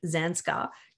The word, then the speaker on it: Zanskar: Now, Amy, I have you here today specifically to talk about your latest number one Amazon best-selling book, Adventure in Zanskar. Do I say that right? – Yes, that's exactly how you pronounce it.